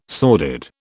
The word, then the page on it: sordid: British English